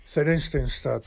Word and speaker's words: Theresienstadt: Theresienstadt.